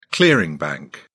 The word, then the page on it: clearing bank: British English